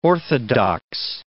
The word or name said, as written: orthodox